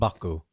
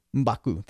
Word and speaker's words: Mbaku: The ones who survived Cap, Iron Man, Thor, Black Widow, Hulk, War Machine, Rocket, Nebula, Mabaku, um, who was the. – You need to learn how to pronounce that, but Mbaku, go on. Mbaku. Mbaku.